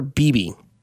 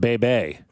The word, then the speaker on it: Bibee: It'll be the Royals' Michael Wacha, who carries an 11-6 record, a 3.32 ERA, against Guardians' Tanner Beebe. I believe that's how you say that name. B-I-B-E-E. 10-6. – I think it's pronounced Bebe.